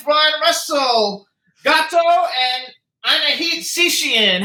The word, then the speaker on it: Setian: His last name is pronounced Setian. – Oh, Setian. I messed up the last name.